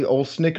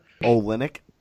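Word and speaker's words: Olynyk: Kelly Olsnick or whatever? – Olenek.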